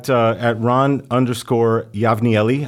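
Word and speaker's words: Ron Yavnieli: Yeah, at Ron_Yavnieli. I'll better spell that one. – Yeah, Y A V as in Victor, N as in Nancy, I E L I.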